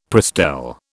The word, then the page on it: Prestel: British English